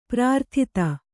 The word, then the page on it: ಪ್ರಾರ್ಥಿತ: kannada